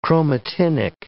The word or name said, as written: chromatinic